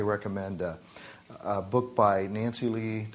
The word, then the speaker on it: Leigh: The second name is spelled L-E-I-G-H. Pronounced Lee, I'll skip this and say something else.